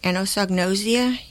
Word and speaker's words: anosognosia: Karen mentioned anosognosia. Another pronunciation is anosognosia. You'll hear it both ways.